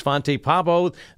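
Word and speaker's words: Svante Pääbo: So raise a cup of coffee to the researchers Fonte Pabo, the winner. I think I got his name right. Yep, that sounds right.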